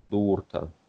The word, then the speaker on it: дурта: Да, значит, у у нас э, произносится сильно. Это сильная буква. – Например, mm-hmm. – У. Например, камни. Дурта.